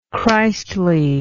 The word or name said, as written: Christliness